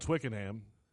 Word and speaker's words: Twickenham: It's spelled Twickenham, but we're not in Alabama, so it's Twickenham.